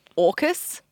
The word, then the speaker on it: AUKUS: He was also the key architect to the AUKUS. – Yeah, that's how you say it.